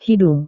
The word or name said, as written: hidung